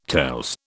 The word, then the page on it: tales: British English